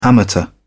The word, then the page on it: amateur: British English